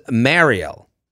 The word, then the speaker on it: Mariel: My name is Mariel. Pronounced like Ariel, the Little Mermaid, with an M. So did I pronounce this correctly? Mariel? – I've heard this name before, yeah.